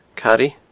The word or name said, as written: Caraidh